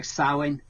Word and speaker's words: Samhain: So it's a bit like Samhain.